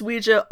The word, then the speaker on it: Ouija: The Ouija board, pronounced Ouija, so there's that argument put to fucking bed. Yeah, it's pronounced Ouija, like, stop.